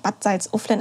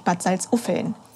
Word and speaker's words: Bad Salzuflen: Äh, Nico, du hast es völlig falsch ausgesprochen. Ich komme ja aus der Region und das heißt nicht Bad Salzuflen, es heißt natürlich Bad Salzuffeln. – Oh. – Ja, auch wenn das L schriftlich vorgestellt wird, es heißt Bad Salzuffeln.